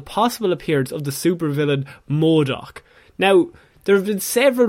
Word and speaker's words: MODOK: MODOK? Is that as... – MODOK.